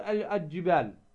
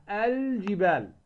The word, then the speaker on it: الجبال: كثير من الناس تجد يقول الجبال الجبال الجبال، هذا خطأ. هنا أنت ماذا صنعت؟ أدغمت. والجيم من أحرف الإظهار التي إذا جاءت بعد لام التعريف نظهر لام التعريف. إظهار لام التعريف يعني النطق بها، هذا معنى إظهارها، اللي هي اللام القمرية. فالجيم ماذا؟ من أحرف إظهار ماذا؟ لام التعريف. فعندما تقول الجبال لا تقول الجبال، الجبال الجبال، لأن الجيم ماذا؟